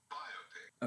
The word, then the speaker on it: biopic: Well, the British one is biopic. – Okay. – And the U.S. one is.